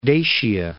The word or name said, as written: Dacia